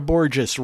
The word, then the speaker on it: Borges: Before that, singer-songwriter Sarah Borges rhymes with gorgeous.